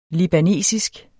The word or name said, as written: libanesisk